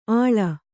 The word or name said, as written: ಆಳ